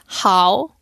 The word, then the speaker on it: 好: China, they pronounce it as hao. – Hao. – Yeah, but in Korea, we say ho. – Mm. Also in Hong Kong, Cantonese ho. – Oh. – Uprising tone. Ho.